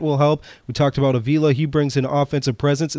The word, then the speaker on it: Avila: I've been told it's Avila, but now that when I was in the room, Al Avila said his name was Avila, so that's how it will work out.